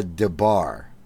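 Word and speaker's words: Des Barres: And the thing that struck me about them was that one of them is Pamela DeBar, which... it's spelled like Des Bars.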